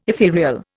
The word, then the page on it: etherial: Indian